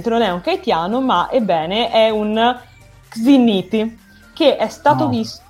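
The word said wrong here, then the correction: Xindi: Non si pronuncia così. – Come si pronuncia? Correggimi, te ne prego, non lo so dire. – Si pronuncia tipo Xinti, qualcosa del genere.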